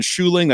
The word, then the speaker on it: Schuiling: I'm on Twitter at Ryan Schuling. That's a Dutch last name. It's weird. I'll spell it for you. S-C-H-U-I-L-I-N-G.